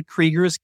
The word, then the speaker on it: Krieger: Brownell actually refers to them as the Gregors, G-R-E-G-O-R. We generally refer to them today as the Kriegers, K-R-I-E-G-E-R, though on their gravestones, it's Krieger, K-R-I-G-R.